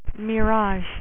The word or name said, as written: mirage